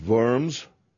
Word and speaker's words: Worms: In January 1076, Henry IV, after a council of German nobles and bishops at Worms, pronounced Worms, because it's German, denounced Gregory as not pope, but false monk, and demanded that he descend from the papal throne.